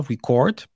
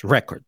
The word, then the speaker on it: record: Em inglês, nós passamos muitas vezes de uh, verbos que têm a, a, sílaba tónica no final, record, para nomes que têm a sílaba tónica mais para trás, record.